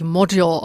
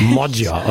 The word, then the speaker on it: Magyar: Und spricht man ihn so richtig aus? Magyar oder Magyar? – Was wissen wir über ihn? Ja, also wir wissen, dass er sich Modyar ausspricht. – Modyar, okay.